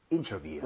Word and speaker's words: interview: 정답, interview. – 음. – Very, very nice. 발음 주의하셔야 되는 게요. 거기 view이기 때문에 네.